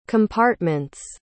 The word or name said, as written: compartments